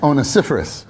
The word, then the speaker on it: Onesiphorus: That's not how I used to pronounce it, but that is the pronunciation. I studied it this time. It's Onesiphorus.